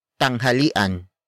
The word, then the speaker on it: tanghalian: It is an object marker that introduces what they are currently eating, and that is tanghalian. Tanghalian. Tanghalian. Tanghalian is spelled as T-A-N-G-H-A-L-I-A-N.